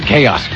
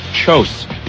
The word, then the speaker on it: chaos: Scott was 35 years old and had never heard the word chaos pronounced out loud. It was one of those things he'd only seen on the page. To him, it was chose.